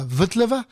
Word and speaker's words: Vltava: Vous connaissez certainement la fameuse Moldau, ce poème symphonique qui parle du fleuve qui traverse Prague, qu'on appelle aujourd'hui la Vltava, pour essayer de le prononcer à la tchèque.